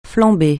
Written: flambée

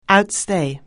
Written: outstay

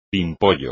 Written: pimpollo

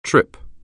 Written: trip

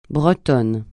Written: breton

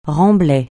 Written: remblai

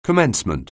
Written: commencement

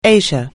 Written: Asia

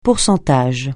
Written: pourcentage

